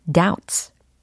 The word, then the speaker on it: doubt: 철자에는 b가 있지만 소리 b는 절대 나지 않는다. 요게 팁입니다. 네, 그래서 요거 발음을 하면은 doubt, doubt, doubt 이게 단수 형태가 되는 거고 s 복수 형태의 어미가 붙었으니까 doubts, doubts라고 들리는 거죠.